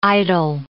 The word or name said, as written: idle